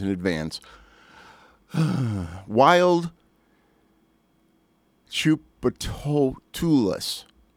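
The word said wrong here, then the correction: Tchoupitoulas: Chupitoulas. I pronounced it Chupatulas. So, yeah, they're wild, man.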